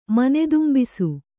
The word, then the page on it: ಮನೆದುಂಬಿಸು: kannada